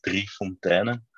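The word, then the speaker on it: Drie Fonteinen: Well, actually, you were very close. It's Dreifontanen. So Dreifontanen. So yeah, it's a bit more the Dreifontanen, a bit more pronounced in a kind of a Flemish or really Dutch way.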